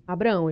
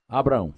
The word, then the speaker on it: Abraham: Abraão, ele fala. – Abraão.